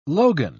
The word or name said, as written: logan